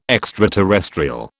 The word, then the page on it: extraterrestrial: British English